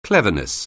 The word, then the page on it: cleverness: anglais